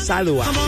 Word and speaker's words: Salwa: Porque se escribe Salwa, pero se lee o se pronuncia Salwa.